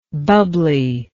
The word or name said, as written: bubbliness